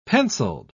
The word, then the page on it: penciled: British English